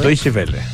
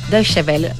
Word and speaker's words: Deutsche Welle: Porque hay un reportaje publicado por la Deutsche Welle, ¿cómo se dice? Deutsche Welle. Deutsche Welle. – Deutsche Welle. – Uy, mi alemán está cada día mejor.